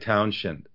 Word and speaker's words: Townshend: When you say Shen, you don't add an H. It's just, you pronounce it like Townshend, you know.